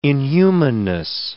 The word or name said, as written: inhumanness